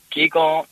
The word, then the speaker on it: Qigong: Oh, like Qigong is energy movement. – That's how you say it. So I said it wrong as well. – Yeah, like Qigong, Qigong, Qigong is same. – Yeah. – Depends on the country.